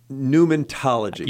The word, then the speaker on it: pneumatology: Well, now we're talking about pneumatology. – You got it. – Did I say it right finally? – That's 2 in a row.